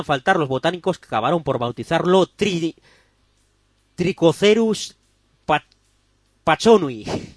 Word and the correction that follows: Pachanoi: Pachanoi, joder. Joder, no. Joder la pronunciación, decía yo. Pronunciado Trichocereus Pachanoi.